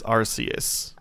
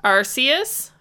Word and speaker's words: Arceus: Some people are saying Pokemon Legends Arceus, and some people are saying Pokemon Legends Arceus. – Hold on, I'm so I don't know. And you know how I mispronounce things all the time. – Okay, Google, and that random guy on YouTube says Arceus.